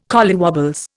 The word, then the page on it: collywobbles: Indian